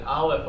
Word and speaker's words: Aleph: The first hint is that, unlike the rest of the alphabet, Aleph is the only silent letter. All the other letters of the alphabet have a physical sound associated with them. Aleph,